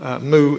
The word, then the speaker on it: Moo: It's this one in the Tyndale series by Douglas Moo. Moo is spelt as in the sound made by a farm animal.